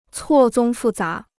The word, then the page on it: 错综复杂: cuò zōng fù zá